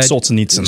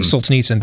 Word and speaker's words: Solzhenitsyn: I think it was Solzhenitsyn. I can never pronounce the name right. That said Solzhenitsyn. – Solzhenitsyn, thank you.